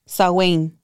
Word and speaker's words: Samhain: Samhain.